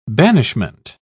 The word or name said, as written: banishment